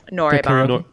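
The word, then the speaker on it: Noraebang (노래방): Noraebang.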